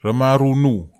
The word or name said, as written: ramaru nuw